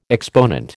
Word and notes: exponent: American English

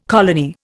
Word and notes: colony: Indian

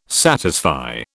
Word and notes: satisfy: British English

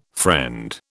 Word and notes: friend: British English

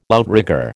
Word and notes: outrigger: American English

